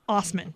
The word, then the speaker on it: Osman: No, it's Osman. That's it is pronounced... – Osman. – Osman, but it is spelled ass man.